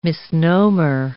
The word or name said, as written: misnomer